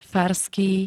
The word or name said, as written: farský